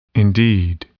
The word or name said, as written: indeed